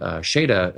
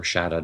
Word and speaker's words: Shada: But he also wrote Shada, or Shada, depending on how you want to pronounce it, which was never televised.